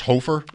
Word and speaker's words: Hofer: This guy's name is pronounced Hofer. – I think that's right. – It should be. – I think so. – H-O-F-E-R. It should be H-O-F-er.